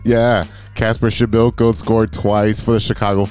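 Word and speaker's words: Przybyłko: because it's spelled Przybylko, but it's pronounced Schibelko.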